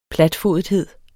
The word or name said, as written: platfodethed